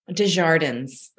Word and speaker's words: Desjardins: Desjardins. – Desjardins. So it's a French pronunciation.